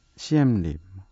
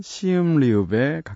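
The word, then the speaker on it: Siem Reap: 저희가 이걸 CM립이라고 쓰고는 있는데, CM립, 시험리업, 시험레아프, 시음리업, 네. 시온미움, 이래도 되겠네요. 이렇게 여러 가지 발음으로 불리고 있지만 표기상으로는 CM립이라고 쓰는데요. 현지 발음으로는 시음리읍에 가깝다고 합니다.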